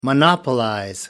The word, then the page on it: monopolize: American English